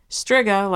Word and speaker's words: Striga: I always pronounce Striga as Striga rather than Striga or long-eyed Striga. Interesting. Striga, like Shiva, and if Chooch is reading this, Shiva is pronounced Shiva.